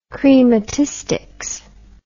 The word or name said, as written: chrematistics